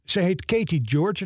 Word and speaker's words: Gyorgy: Het wordt geschreven met G-Y-O-R-G-Y. En voor iedereen die wel eens in Hongarije geweest is, die weet dat die laatste Y niet wordt uitgesproken. Of althans, niet op die manier. Dus het moet uitgesproken worden als George.